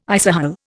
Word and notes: isohel: Indian

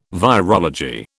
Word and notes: virology: British English

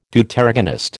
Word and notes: Deuteragonist: American English

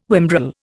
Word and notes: whimbrel: Indian

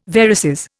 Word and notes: varices: Indian

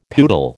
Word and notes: poodle: American English

